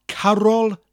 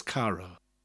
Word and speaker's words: Carol: And one of the things we like to sing Christmas time are Carol Nadolig, a Christmas carol. Carol is carol, spelled exactly the same way, you just slightly pronounce the R.